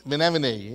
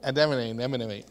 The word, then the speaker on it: anemone: What is that word that Nemo said? Menemone, yeah. Anemone.